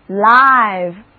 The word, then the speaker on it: live: Live 这个单词同时也是一个形容词，注意哦，这个时候字母 I 要发它的本音了，读作 I live, 意思是活的、有生命的、现场直播的。